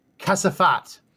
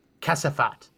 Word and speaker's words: Kasafat: Kasafat is how, if you were in Afghanistan, you would say trash. It would be like trashy, like a trashy person. Kasafat is how you pronounce it.